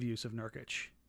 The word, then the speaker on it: Nurkic: I love the idea of Yusuf Nurkic. He has been outplayed for what, 5 straight games? – I think you said that properly the second time.